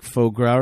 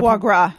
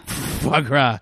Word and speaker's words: foie gras: Shigra, which sounds like a fattened liver, like faux gras or something like that. How do you pronounce that? Did I pronounce that? – Foie gras. – Foie gras.